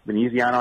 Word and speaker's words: Veneziano: Veneziano, I think, is actually how it's said.